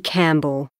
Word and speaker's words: Campbell: My name's Maggie Campbell. – Is that C-A-M-P-B-E-L-L? – Yes.